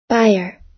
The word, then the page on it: buyer: American English